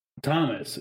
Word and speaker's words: Thomas: To be fair, my name Thomas is spelled T H O M A S.